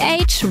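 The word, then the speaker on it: Aitch: Mit Age, Rapper aus UK, der übrigens eigentlich Harrison heißt. Daher aber auch sein Künstlername Age, weil das H vorne in Harrison, also der Buchstabe, wird englisch ja Age ausgesprochen.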